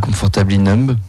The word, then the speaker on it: Comfortably Numb: Comfortably Numb.